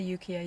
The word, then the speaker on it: Ukiah: So Stephen actually then left the house with Timothy and they hitchhiked to Ukiah, or I'm not sure if I'm pronouncing it right, but I'd say Ukiah, Ukiah.